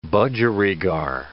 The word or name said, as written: budgerigar